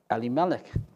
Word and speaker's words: Elimelech: Now, that's actually probably not the way he pronounced his name, because in Hebrew, if you know any little Hebrew, you know that the emphasis would be on the Eli rather than Eli. So it's probably Eli, and it's probably Eli Melech is the actual probably pronunciation of his name.